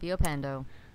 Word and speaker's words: Villapando: No, there's the L's are silent. Villapando. – Well, I've been saying it wrong the entire time.